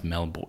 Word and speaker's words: Melbourne: Sorry. – Say it again. – Melbourne? – Yeah, it's better. – It's pronounced Melbourne. It's spelled Melbourne. What do you say? How do you say Jason Bourne? – Bourne.